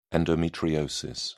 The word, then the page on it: endometriosis: British English